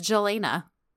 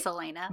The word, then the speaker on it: Celaena: to realize I was pronouncing her name with a J sound, like it was Turkish. Like, her name is spelled C-E-L-A-E-N-A. And in my head, the entire time I was reading, without even questioning it, I was like, oh, her name is Jelena. – Yeah, but it's Selena.